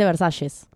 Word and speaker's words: Versalles: Claro, pero se dice Versalles, como lo decimos nosotros.